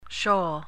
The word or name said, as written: shoal